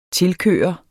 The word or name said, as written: tilkøre